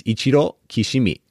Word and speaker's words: Ichiro Kishimi: I happen to grow up in Japan, so I'll give you the pronunciation. It's Ichiro Kishimi how they would say it in Japanese.